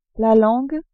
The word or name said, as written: langue